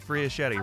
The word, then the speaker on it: Fiaschetti: I hear Bernadette Friachetti, rhymes with spaghetti.